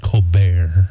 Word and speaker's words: Colbert: I think it's actually pronounced Colbert. – Colbert. – It's deceiving, but Stephen Colbert.